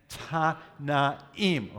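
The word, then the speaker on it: Tannaim: And it was written by the rabbis that are called, now this is a little harder to say, Tanaim. The syllable turns on a vowel. We're not used to that in English. We turn syllables mostly on consonants, but Tanaim. Again, Tanaim.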